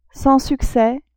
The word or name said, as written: sans succès